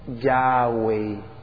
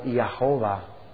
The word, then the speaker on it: Yahweh: Y salía sonando algo como Yahweh. O ya, en griega, en español, Yahweh. Si lo pronunciamos en español. En inglés suena como Yahová. Y de ahí es donde deriva la voz que en algunas versiones de Biblia sale como Yahvé, y en otras sale como Jehová.